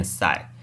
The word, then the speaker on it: PSY: 首先呢，大家看到我的 Podcast 名称啦 PSY 告解室，PSY 到底怎么念呢？PSY 念赛。